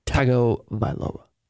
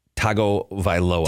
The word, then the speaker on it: Tagovailoa: Tagovailoa. – Tagovailoa.